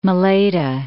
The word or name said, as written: Malaita